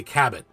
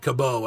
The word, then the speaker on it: Cabot: And two, I'm the last person in the world that will ever do this to somebody, but I believe it's Mary Kay Cabot. I don't think the T is silent. You said Cabot. I believe it's Cabot.